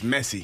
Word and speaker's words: Messi: Little train. Messi. – It's just messy.